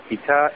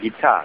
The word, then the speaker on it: Gita: And I'm going to start with those two quotations, one from the Gita, Gita, and one from, how do you say it, Gita, right? – Yes, Gita, that's right.